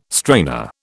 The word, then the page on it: strainer: British English